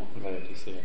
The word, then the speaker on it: Laodicea: How do you say it in English? Laodicea? – Laodicea. – Oh, you say, okay. Sometimes we say it differently.